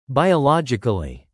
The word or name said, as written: biologically